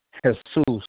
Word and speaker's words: Jesus: Okay, my first name is pronounced Jesus, and it's spelled just like Jesus. And my last name is Redondo. That's R-E-D-O-N-D-O.